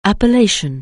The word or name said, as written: Appalachian